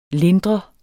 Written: lindre

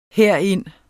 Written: herind

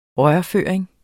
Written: rørføring

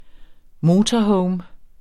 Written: motorhome

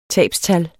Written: tabstal

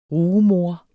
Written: rugemor

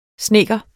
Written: snedker

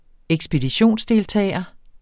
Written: ekspeditionsdeltager

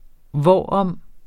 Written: hvorom

